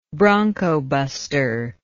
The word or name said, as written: broncobusting